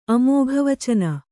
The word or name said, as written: ಅಮೋಘವಚನ